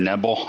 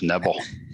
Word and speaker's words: Knebel: It's Corey Knebel. I think it's just Knebel. – Is it? – Yeah, Corey Knebel.